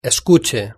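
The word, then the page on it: escuche: Spain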